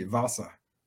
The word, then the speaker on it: Vasa: Well, you know, it's funny in German, the Vasa is pronounced like a V, Vasa.